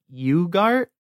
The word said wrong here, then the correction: Ugarte: I think. – Ugarte, I think is how they say yeah.